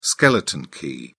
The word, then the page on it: skeleton key: British English